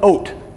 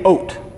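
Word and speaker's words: oat (אות): Now, a sign in Hebrew is called an oat. Everybody say oat. In Hebrew, it's spelled aleph, vav, tav.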